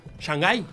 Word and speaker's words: Shanghái: ¿Cómo se dice? Shanghai. ¿Shanghai? – Sí.